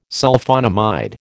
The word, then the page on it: Sulphonamide: American English